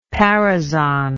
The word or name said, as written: paraison